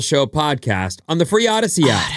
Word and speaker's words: Audacy: A-U-D-A-C-Y. It's spelled funky. It's like a made-up word. We made it up. It's audacious. It's audio. It's awesome. It's like a trip. – Go on. – A-U-D-A-C-Y.